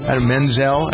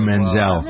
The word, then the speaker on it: Menzel: Menzel? I always mispronounce her name. Idina Menzel.